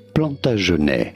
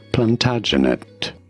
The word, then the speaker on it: Plantagenet: For reference, in French, this is normally said as Plantagenet. In English, however, it is usually pronounced as Plantagenet.